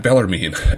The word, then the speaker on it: Bellarmine: Am I saying that wrong? – Bellarmine, I believe.